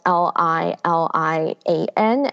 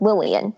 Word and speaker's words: Lilian: Like for example, there is a character whose name is spelled L I L I A N, and in Mandarin that's pronounced as Li Lian, but to an English speaker that looks like Lillian, right?